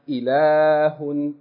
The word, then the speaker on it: إِلَاهٌ: All we're saying is that there are certain frequently used words in Arabic language in which the aleph in those words of elongation is not written, but you have to pronounce it. Okay, so you learn those words. Whenever you see them, even though you don't see no aleph written there, you pronounce it as though there's an aleph. Okay, you say, هَذَا, هَذِهِ, لَكِنَّ, إِلَاهٌ, like this.